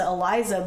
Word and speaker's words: ELISA: They pronounce it Eliza, but it's spelled Lamb Elisa.